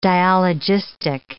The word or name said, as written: dialogistic